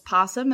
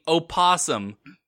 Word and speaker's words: opossum: Now, I will clarify, in this story, whoever wrote it decided to go with just possum instead of opossum. There is some debate as to whether or not the O is silent, which I thought was really interesting in my research.